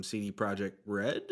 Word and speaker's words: CD Projekt Red: I would say, too, I feel like some of these announcements that have come out from CD Projekt Red, is that right? Did I say that right? – Yes. Yeah, you said that right.